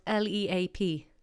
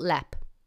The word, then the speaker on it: Leap: And what about that town that's spelled L E A P? Leap. No, it's called Lep. And what about that Tipperary place?